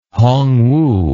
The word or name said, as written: Hongwu